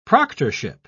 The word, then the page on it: proctorship: British English